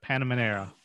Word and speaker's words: Panamera: I bought a Porsche Pan- Panamera.